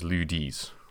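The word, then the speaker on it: lieu-dit: And those are called, referred to those as Ludis, or they might be like subplots of certain vineyards. And L-I-E-U space D-I-T is how you spell that.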